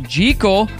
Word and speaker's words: Jekyll: Dr. Jekyll from Dr. Jekyll and Mr. Hyde was meant to be pronounced Jekyll. That's how Robert Louis Stevenson intended it when he wrote the original story.